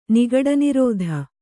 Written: ನಿಗಡನಿರೋಧ

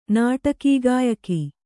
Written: ನಾಟಕೀಗಾಯಕಿ

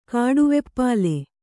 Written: ಕಾಡುವೆಪ್ಪಲೆ

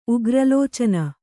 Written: ಉಗ್ರಲೋಚನ

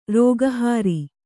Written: ರೋಗಹಾರಿ

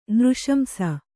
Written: ನೃಶಂಸ

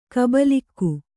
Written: ಗುಬಲಕ್ಕಿ

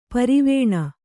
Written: ಪರವಾನೆ

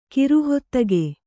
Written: ಕಿರುಹೊತ್ತಗೆ

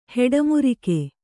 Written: ಹೆಡಮುರಿಕೆ

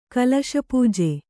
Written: ಕಲಶಪೂಜೆ

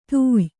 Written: ಧೇಯ

